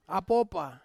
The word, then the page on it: apopa: Ansus